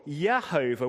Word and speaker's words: Jehovah: To get the name Jehovah or Yehovah, which some of us would have said was God's personal name, pronounced with a Y and not with a J despite its spelling,